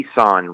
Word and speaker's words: Isaan: And the region that we went to is called the Isan region. It's spelled I-S-A-A-N.